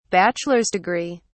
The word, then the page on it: bachelor's degree: British English